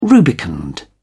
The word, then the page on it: rubicund: British English